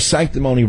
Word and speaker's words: sanctimony: And by the way, sanctimony rhymes with phony and baloney.